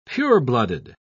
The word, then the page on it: pureblooded: British English